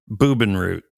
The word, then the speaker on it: Bubenreuth: So they go to nearby, called Bubenreuth. – I get the feeling you are pronouncing German like I pronounce Spanish. – That could very well be. – Bubenreuth.